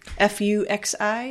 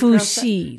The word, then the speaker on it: Fuxi: Uh, F U X I is that how uh, you would Fuxi. Uh, that? Fuxi. That would be Fuxi.